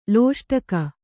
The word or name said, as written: ಲೋಷ್ಟಕ